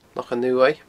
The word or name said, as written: Lochan Uaimh